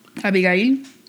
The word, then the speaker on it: Abigail: Her name was Abigail. I'll just call her Abby. Let's just call her Abby for short. – I like the way Abigail sounds in Spanish.